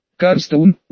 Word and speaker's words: Карстоун: Карстоун, ударение на первый слог, а не на второй. Я, конечно, понимаю, что удобнее произносить с ударением именно на второй слог, но правильнее это делать именно на первый.